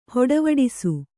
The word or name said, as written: ಹೊಡವಡಿಸು